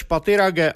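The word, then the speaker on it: Pathirage: Vyslovoval své jméno Rumeš Patirage a vyloučil anglickou výslovnost svého příjmení Patirage.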